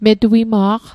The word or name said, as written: metuima'q